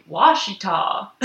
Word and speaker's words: Ouachita: So they were all like Washita.